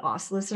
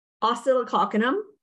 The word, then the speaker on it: Oscillococcinum: This homeopathic, you know, I honestly, we call it oscillus at our house. That's not how you pronounce it. It's like oscillococcinum is how you pronounce it.